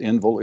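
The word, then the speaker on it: Endville: and I think it's pronounced Enville, it's E-N-D-V-I-L-L-E, and it's in Pontotoc County, somewhere between Tupelo and Pontotoc.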